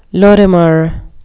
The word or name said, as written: lorimer